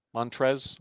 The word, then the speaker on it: Montrezl: You also said Montrezl. – The Brown family. A lot of Browns. – Montrezl Harrell. It's just Montrez. The L is silent.